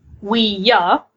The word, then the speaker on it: Ouija: Ouija board is actually meant to be pronounced "we-ya," as in yes, we in French and ja in German.